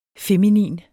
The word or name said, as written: feminin